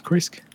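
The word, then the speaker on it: Krezk: It's pronounced Kresk.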